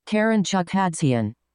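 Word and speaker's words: Karen Chukhadzhian: So Jake Donovan said Karen Chudvakin. I don't even. I, I'm sure I said that wrong. Let's get Jeffrey on it. Jeffrey. – Karen Chukhadzian.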